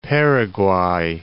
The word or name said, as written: Paraguay